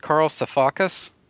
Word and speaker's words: Sifakis: How do you pronounce this? Carl Sifakis? – Yes, it's some Greek name.